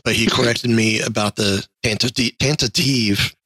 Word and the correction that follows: Tantive: God, I can't say it right. – The tantive. – Tantive four. Yes. – Yeah, there's only two T's.